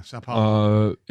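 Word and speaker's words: São Paulo: Yeah, Sao Paulo.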